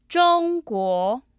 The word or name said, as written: zhongguo